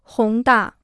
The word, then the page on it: 宏大: hóng dà